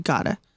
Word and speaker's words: Gata: Our secretary wrote Gotta, G-O-T-T-A. – I had to ask them how to pronounce it because it's actually spelled G-A-T-A. I'm like, Gata? Gata? Was it long A, short A, what? And so I asked them, it's actually pronounced Gotta. – Okay. So it's G-A-T-A, but it's pronounced Gotta.